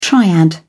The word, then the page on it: triad: British English